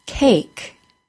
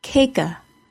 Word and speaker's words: cake: Our key word for the long A is cake. C-A-K-E. The A is said as a long A and the E is silent. Cake. I do not say cake-a. I do not need to make any sound at all for the E at the end of the word.